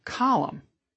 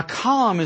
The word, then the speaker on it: column: Have you ever wondered about that silent N in the word column? A column is written by who? A columnist.